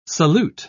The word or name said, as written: solute